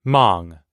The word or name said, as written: mong